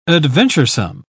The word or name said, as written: adventuresome